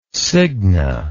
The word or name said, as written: signa